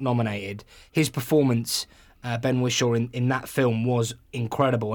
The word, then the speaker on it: Ben Whishaw: Is it Ben Whishaw? – Yes.